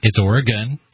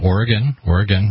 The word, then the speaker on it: Oregon: It's Oregon. It's a schwa. Oregon. Repeat after me. Oregon.